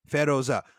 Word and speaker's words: Feroza: Feroza. It's the word for turquoise. – Oh, really? Okay. – Yeah. – Feroza. Okay, so it's like Fair and Rosa put together.